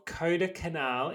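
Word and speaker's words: Kodaikanal: So in March 2001, residents of Kota Canal in southern India, I totally butchered that by the way. – It's perfect.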